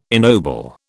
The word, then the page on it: ennoble: British English